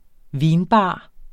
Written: vinbar

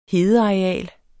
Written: hedeareal

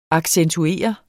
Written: accentuere

